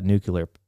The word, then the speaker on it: nuclear: When you tap in spiritually, then you get that nuclear power. Did I say that right? Nuclear. – Yeah. – I just did that George Bush thing. nuclear. Anyway, nuclear. – Just like apricot.